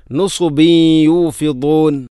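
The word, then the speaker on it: നുസുബി യൂഫിദൂൻ (نصب يوفضون): നുസുബി യൂഫിദൂൻ. ബ എന്ന അക്ഷരത്തിൽ കസ്റു തൻവീൻ, തൻവീനു ശേഷം യ വന്നാൽ ഇദ്ഗാം നാഖിസ് എന്ന നിയമമാണ്. അവിടെ തൻവീനിനെ യയിലേക്ക് കൂട്ടി ലയിപ്പിച്ചുകൊണ്ട് ഷദ്ദില്ലാത്ത രൂപത്തിൽ ഉന്നത്തോടുകൂടി ഓതണം. നുസുബിയൂഫിദൂൻ.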